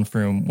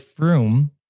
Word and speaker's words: Frum: This version was backed up in the 1940s by the island administrator, Alexander Ratul, who said that the word Frum is actually pronounced like the word broom in Tannese.